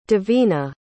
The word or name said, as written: divina